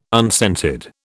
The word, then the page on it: unscented: British English